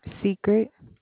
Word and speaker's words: Secret: Secret. – Spell that, please. – S-E-C-R-E-T.